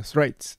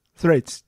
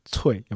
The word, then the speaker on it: Threads: 我觉得之前有一阵子不是有那个好像是 Amazing Talker 还是谁，好，就有一群这个英文老师就说这个台湾人很不会念 th 这个发音，就他的舌头伸不出来，所以 threads 就是一个例子，大家直接把它喊成脆，有没有？